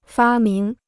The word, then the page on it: 发明: fā míng